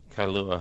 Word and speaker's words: Kailua: Kailua, Hawaii. – Kailua, Hawaii.